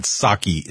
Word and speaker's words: Psaki: And, of course, the spokesperson for Biden is Psaki, Jennifer Psaki. Now, remember, the P in Psaki is silent.